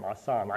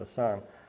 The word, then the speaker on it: asham: A-S-H-A-M, asam. I pronounce it asam.